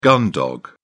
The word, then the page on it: gun dog: British English